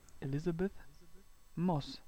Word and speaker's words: Elizabeth Moss: Äh, Elizabeth Moth für The Handmaid's Tale. – Sag das bitte nochmal, den Namen. – Elizabeth Moss. – Schade, du hast gerade eben so schon so Moss. Elizabeth Moss.